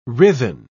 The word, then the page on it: writhen: British English